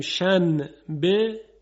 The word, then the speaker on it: شنبه: در فارسی هم داریم شن به، چی تلفظ میکنیم؟ شنبه، یعنی به جایی که نون بگیم میم میگیم.